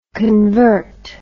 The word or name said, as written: convertive